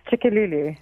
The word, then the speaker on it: Tshikululu: It's Tshikululu, right? – Tshikululu. – Tshikululu. There we go.